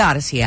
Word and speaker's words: Audacy: It's free, but it's spelled wrong. It's spelled AUDACY. Think audio, audacious, and then a musical journey, an odyssey.